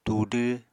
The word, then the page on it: tudw: Apatani